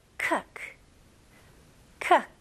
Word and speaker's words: cook: 여기서는 많은 분들이 cook이라고 발음하시죠. 아닙니다. 이거는 으 발음과 더 가깝고요. 그래서 cook, cook. 좋아요. 이거는 look, book. Cook 다 똑같은 발음이에요.